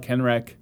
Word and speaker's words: Kenreck: I also have a fan page, and I am just Todd Kenreck, not Kendrick. Todd Kenreck on Twitter.